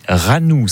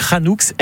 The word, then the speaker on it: Ranoux: Bon, Anne-Marie, vous, votre nom de famille, c'est Ranou, c'est ça? – C'est ça. – R-A-N. – Et on prononce même le X. – Hein. Ah, on dit Ranoux. Ok, très bien. Bah, vous m'apprenez ça, vous voyez. Donc, Ranoux, R-A-N-O-U-X.